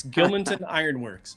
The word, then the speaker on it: Gilmanton Iron Works: The pastor of Harvest Bible Church in Gilmanton Iron Works, New Hampshire. So New Hampshire's got some cool city names. Did I say that right? – Pretty close. Yeah, Gilmanton Iron Works, absolutely.